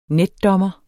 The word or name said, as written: netdommer